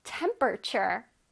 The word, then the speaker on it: temperature: Temperature. What is it? Temperature. 오늘의 하이라이트입니다. Temperature. Temperature. 어, 발음 잘하고 있어요? 바로 온도, 기온이란 뜻이에요. Temperature. 자, 앞에 T-E-M 부분을 강조해서 한번 발음해보자.